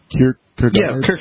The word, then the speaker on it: Kierkegaard: Kierkegaard.